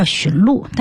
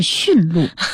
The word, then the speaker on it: 驯鹿: 就是在西方的那个神话有一个什么圣诞节里边有一个拉雪橇的驯鹿，我们通常人都管它叫驯鹿，但是其实正确发音是驯鹿。